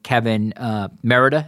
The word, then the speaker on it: Merida: My question for you, and clearly you wanted to be a part of that, and now I think Kevin Merida, am I pronouncing his name right? – Yes.